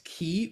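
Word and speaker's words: ki (生): Here it's pronounced ki, which has a nuance of life.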